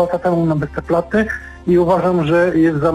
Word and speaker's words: Westerplatte: Otóż Westerplatte to nazwa pochodząca z języka niemieckiego i z niemiecka powinna być wymawiana, to znaczy przez jedno T w ostatniej sylabie, zatem Westerplatte.